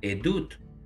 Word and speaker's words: edut: Ahora, el tercer, la tercera categoría que se pronuncia edad o en plural edut, que significa prueba, testimonio, prueba y testimonio.